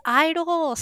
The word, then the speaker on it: I-DLE: It's I-DLE. Stop saying it wrong. We've only said it wrong 500 times.